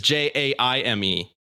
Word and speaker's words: Jaime: We are saying his name wrong. I realized I've been misspelling his name the whole time. It is Jaime. I thought it was Mie. – Oh, yeah, Jaime.